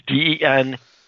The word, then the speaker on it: Deneuve: It's DeNeuve.